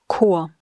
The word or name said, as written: Chor